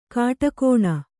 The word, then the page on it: ಘಟಾಗ್ನಿ: ghaṭāgni